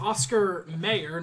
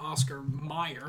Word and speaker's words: Oscar Mayer: It's Oscar Mayer, not Oscar Meyer.